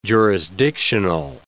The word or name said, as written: jurisdictional